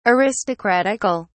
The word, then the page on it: aristocratical: British English